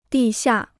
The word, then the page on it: 地下: dì xià